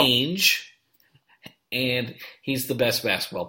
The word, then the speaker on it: Ainge: She probably could have negotiated him up had she known, at least known not to pronounce it Ainge.